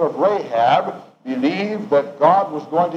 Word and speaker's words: Rahab: Notice in verse 5, "And Salmon begat Boaz," that's Boaz, "of Rahab," generally pronounced Rahab, or R-A-H-A-B.